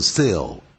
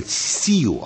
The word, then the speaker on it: sill: 尤其我很注意到一些发音啊，那像 sell window sill it 的音啊，变成 seal 啊，那就不对了啊。